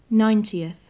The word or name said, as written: ninetieth